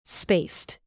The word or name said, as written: spaced